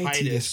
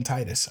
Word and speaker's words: Tidus: They say Tidus, but I call him Titus. I don't care. – I call him Titus too, but I know how it's pronounced.